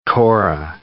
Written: kora

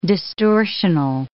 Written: distortional

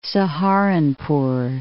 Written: Saharanpur

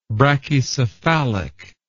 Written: brachycephalism

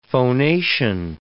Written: phonation

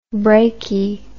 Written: brachi

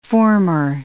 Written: former

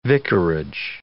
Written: vicarage

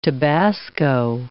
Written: Tabasco

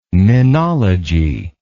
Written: menology

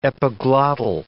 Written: epiglottal

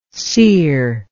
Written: cereless

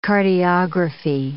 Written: cardiography

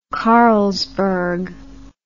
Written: carlsberg

